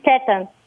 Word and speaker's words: Teton: Ils disent-tu Tetons ou ils le prononcent d'une autre façon? – Ils disent Tetons. – Ah, c'est merveilleux. – Teton, OK. – C'est Grand Teton National Park. – Non, c'est un E. Fait que c'est Teton.